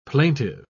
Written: plaintive

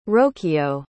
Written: rocio

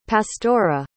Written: pastora

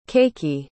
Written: cakey

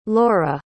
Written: laure

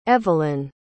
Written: eveline

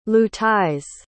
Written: luthais

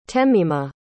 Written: temima

